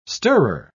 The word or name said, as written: stirrer